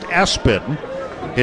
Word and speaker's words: ESPN: That is his legal middle name, ESPN, pronounced Espen. His dad, apparently a big sports fan, and snuck that onto his birth certificate while mom was recovering.